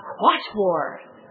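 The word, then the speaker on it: quattuor: It sounds like the quat war. You pronounce four quat war.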